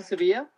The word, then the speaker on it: Sevilla: My name is Ruben Sevilla. It's you need to spell it? – Sure. – It's R-U-B-E-N, and last name is S-E-V, as in Venezuela, I-L-L-A.